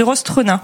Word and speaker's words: Rostrenin: De Rostrenin.